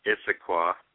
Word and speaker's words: Issaquah: Yes, it's pronounced Issaquah.